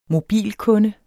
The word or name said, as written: mobilkunde